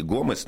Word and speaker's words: Гомес: Он, по идее, Гомес, но поскольку он француз, то мы ставим ударение на последний слог.